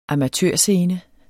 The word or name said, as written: amatørscene